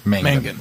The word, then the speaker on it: Mangan: Stephen McGann actually tweeted, Stephen McGann. – I don't think that's how you say his name. – However, his name. – Mangan.